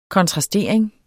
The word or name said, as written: kontrastering